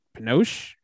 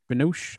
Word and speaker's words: Binoche: Juliette ben- Binoche. Binoche.